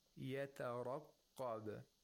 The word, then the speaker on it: يَتَرَقَّبَ: When we want to pronounce يَتَرَقَّبَ, we pronounce it with caution. We pronounce it cautiously. Why? Because we have two letters of tarqiq, one letter of ra with tafkhim, and then a qaf with tashdid, with shatta and tafkhim.